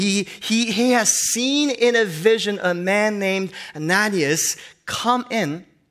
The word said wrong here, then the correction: Ananias: I'm sorry, Ananias. I've been pronouncing his name wrong for some reason. You know why? Because I'm getting the Korean pronunciation and English pronunciation messed up, and you're giving me a lot of grace because no one yelled out, no!